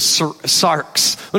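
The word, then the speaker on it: sarx (σάρξ): The flesh in the Greek is sarx. Let me hear you say sarx. Now, we didn't say it correctly because none of us can roll the R appropriately in the Greek, right? But it's sarx, and this is this idea of our sinful human nature.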